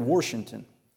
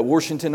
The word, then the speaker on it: Washington: And so we moved to this little podunk school south of Norman, Oklahoma called Washington yeah, you don't, you spell it Washington, but you don't say it like that. It's got an R in there.